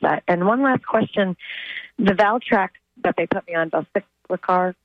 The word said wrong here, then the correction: valacyclovir: I don't know if I'm pronouncing it correctly. – Acyclovir, Valacyclovir. – Yes, that's it.